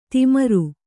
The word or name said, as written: ಧೂಮ್ರ